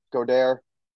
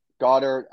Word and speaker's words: Goedert: I like Dallas Goedert, Goddard. – Goddard. – Another running gag. He doesn't know how to pronounce his name.